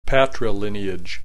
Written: patrilineage

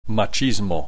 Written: machismo

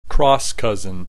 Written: cross cousin

